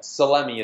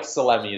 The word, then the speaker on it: Salemi: Yeah, Salemi is my last name. Okay, kind of so, like salami, but with just like my nickname growing up was always Salami Mike. Right. But Mike Salemi is the way it's pronounced.